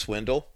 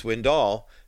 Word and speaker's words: Swindoll: And by the way, in case you're wondering, it actually is pronounced Swindle, but kind of a Southern Texas twang to it. But Al was the one, from what I understand, who said, no, it's Swindoll, because no one wants to hear from a pastor whose last name is Swindle.